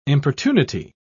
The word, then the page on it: importunity: British English